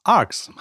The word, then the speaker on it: ARX: Es ist kein Kürzel für irgendwas. Es wird wirklich Arx ausgesprochen.